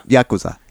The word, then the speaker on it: Yakuza: Yakuza.